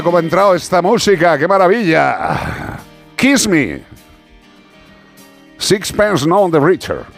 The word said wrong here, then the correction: Sixpence None the Richer: ¿Lo he dicho bien, hija? – Bastante bien. – Bastante bien. – Sí. – A ver cómo lo dirías tú. Sixpence. A ver, la anglófona. – Sixpence None the Richer.